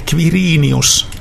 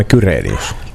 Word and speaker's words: Quirinius: Se on ihan niin kuin KV, Quirinius, hän äänsi itse nimensä. – Mutta miksi me sanomme Kyrenius?